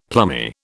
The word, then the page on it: plummy: British English